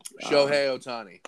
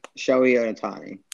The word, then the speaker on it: Shohei Ohtani: Shohei Ohtani. – Shohei Ohtani.